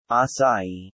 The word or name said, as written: acai